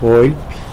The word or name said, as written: golpy